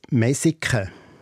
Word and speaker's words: Messikon: Ja, der Ort heißt genau Messikon mit einem S geschrieben in der Mundart ausgesprochen als Messiken.